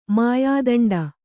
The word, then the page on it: ಮಾಯಾದಂಡ: Kannada